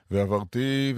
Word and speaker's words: ועברתי: ועברתי בארץ מצרים בלילה הזה, והקיתי כל בכור בארץ מצרים. איך אני יודע? מניין לי שככה יש לבטא? כי סימא טעמי המקרא מופיע על האות תו של ועברתי והקיתי.